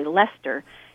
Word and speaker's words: Leicester: So there was this, just for an example, there was a city in, in, called Leicester, or as the British say, Leicester, in uh, the UK, that had a very high vaccination rate, 95% vaccination rate.